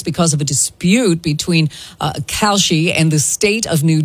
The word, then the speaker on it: Kalshi: I am saying it right, Kalshi? – Yeah, you're right.